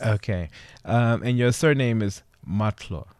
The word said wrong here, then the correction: Mahutla: Mahutla. – Mahutla. Mahutla. Okay.